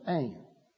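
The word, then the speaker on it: ain (ἦν): The word that's translated was has only two letters, and it's pronounced ain. Ain was, egenomein became.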